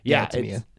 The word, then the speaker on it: Tamia: But yeah, this song is Brandy, Gladys Knight, Chaka Khan, and Tamia, I'm not sure how to pronounce her name, but Tamia.